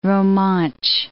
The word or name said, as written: Romansh